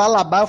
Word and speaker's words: Alaba: Eu, eu chamava, sempre falava Alaba, aí um belo dia eu vi alguém falando Alabá, eu falei, caralho, eu tô falando errado esse tempo todo.